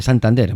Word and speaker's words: Santander: Están formados por Francisco Britones, voz guitarra, Mauricio Sataumber, voz guitarra, Nelson Fuentes, voz bajo, y Jaime Santander, ¿no? Santander, Mauricio Santander, el voz guitarra, que antes lo pronuncié mal.